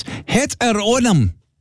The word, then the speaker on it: heteronym: Heteronym.